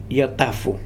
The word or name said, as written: Yatafu